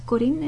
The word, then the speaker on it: Corien: My contact info will also follow at the end, but my name is Corinne, it's spelled C-O-R-I-E-N.